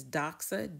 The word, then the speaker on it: doxa: And so glory, when we change that into English or a transliteration, in other words, is doxa, D-O-X-A, and there's the pronunciation.